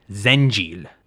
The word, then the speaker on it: Zengil: Michael similarly adapted Saint-Gilles to Syriac, pronouncing it something like Zengil.